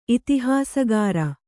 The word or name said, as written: ಇತಿಹಾಸಕಾರ